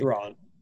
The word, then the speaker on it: Thrawn: And I think that we were talking about him earlier, like, you'll get to see Grand Admiral Thrawn. I say that right? – Thrawn.